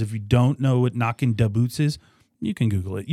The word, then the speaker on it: Knockin' Da Boots: Duh, spelling D A, not a T H E. Da Boots. – And Knockin'. – So you need to say that right. – Apostrophe. – Yeah, there's no G on the end of that. There's no G on the end of that word. So Knockin' Da Boots.